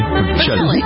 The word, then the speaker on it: Bocelli: She did a duet with the tenor Andrea Bocelli. Is that Andrea Bocelli? Am I pronouncing it right? – Bocelli.